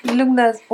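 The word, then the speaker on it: Lughnasadh: Donc c'est la, je prononcerai pas, hein, je sais pas comment on prononce. – La Lugnasa. Lugnasa. Ouais, Lugnasa. – Lugnasa. – Lugnasa, oh oui. – On le dit avec un accent anglais.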